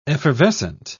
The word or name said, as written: effervescent